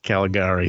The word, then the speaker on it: Caligari: Caligari. Caligari.